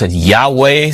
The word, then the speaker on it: Yahweh: And this is sometimes pronounced Yahweh. Some people use in some translation you may see the word Jehovah with a J. The Hebrews did not have the J sound.